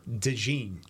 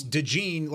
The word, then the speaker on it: DeJean: By the way, based off of the Iowa Athletic Department website, the pronunciation is Cooper DeJean. – DeJean. – So it's DeJean. Like, you've got a little bit of a J in there, but you kind of roll over. Cooper DeJean.